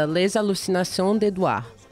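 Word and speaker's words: Les Hallucinations d'Édouard: Era uma versão de uma música francesa chamada Les Hallucinations d'Edouard. Falei certo? Falou.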